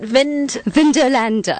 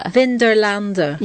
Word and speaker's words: Vindolanda: Vindolanda. – Vindolanda.